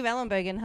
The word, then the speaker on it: Ellenbogen: Eve Ellenbogen, hello.